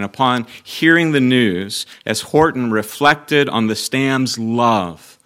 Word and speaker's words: Houghton: Looks like it should be pronounced Houghton, but it's one of those old English names that is not pronounced the way it's spelled. It's Frank Horton.